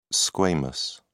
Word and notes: squamous: British English

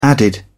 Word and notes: added: British English

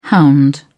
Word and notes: hound: British English

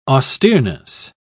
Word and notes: austereness: British English

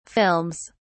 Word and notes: films: British English

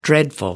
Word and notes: dreadful: American English

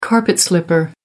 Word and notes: carpet slipper: American English

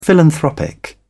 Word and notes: philanthropic: British English

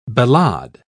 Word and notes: ballade: British English

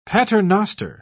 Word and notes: paternoster: British English

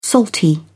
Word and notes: salty: British English

